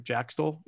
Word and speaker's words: Jack Stoll: So, and then I did ask him about Jack Stoll, and I said that right? Jack Stoll.